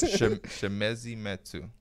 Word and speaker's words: Chimezie Metu: Chimezie Metu.